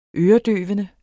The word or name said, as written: øredøvende